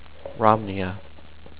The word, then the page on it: Romneya: rom-Knee-aa